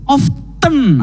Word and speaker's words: often: Tapi ini mungkin pronunciation-nya sedikit salah ya. – Benar, karena ini kata yang sering kan? – Yap, benar sih. – Iya sering sih. – Iya kan? Benar. – Often.